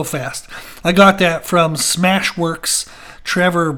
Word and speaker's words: Smash WeRX: It's Smash WeRX. So if you want to check that out on YouTube, S-M-A-S-H, Smash, then W-E-R-X, Smash WeRX. Smash Works is what he says.